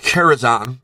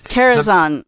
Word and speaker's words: Karazhan: It is pronounced Karazhan. That is... – Karazhan.